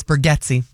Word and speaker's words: Bargatze: No, it's Bargatze. – Are you sure? – Yes. – That's how he pronounces it? – Yeah, and there's a whole feel. That's the correct pronunciation.